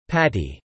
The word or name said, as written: Patty